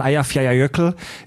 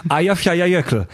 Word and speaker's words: Eyjafjallajökull: Jahrhundert gab es eine riesengroße Hungersnot in Irland, die tatsächlich mit dem Vulkan Eyjafjallajökull in Island zusammenhängt. Ja, ich habe es korrekt ausgesprochen. Danke. – Ich dachte mal, Isländisch wäre unaussprechlich. Auch für Isländer. – Eyjafjallajökull.